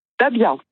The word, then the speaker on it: Dahbia: Dabia. D-A-H-B-I-A. – Dabia. – Oui, comme Azalia, ouais. Mais avec un B.